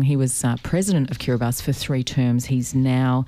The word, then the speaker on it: Kiribati: Kiribati is actually spelled Kiribati, it's B A B A T I on the end, but you pronounce it Kiribati.